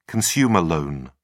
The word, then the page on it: consumer loan: British English